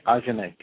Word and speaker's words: Ajahnae: Ajane, A-J-A-H-N-A-E. – Okay. A-J-A-H-N-A-E, Ajane, got it.